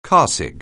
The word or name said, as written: carsick